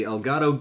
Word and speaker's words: Elgato: Elgato? – Yeah. – Yeah, the Elgato Game Capture HD 60.